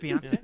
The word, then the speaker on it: Beyonce: Beyonce.